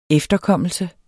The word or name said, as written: efterkommelse